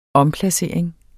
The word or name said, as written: omplacering